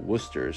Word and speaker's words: Worcester: no, it was actually named after Worcester, England, and over there they actually they pronounce it Worcester.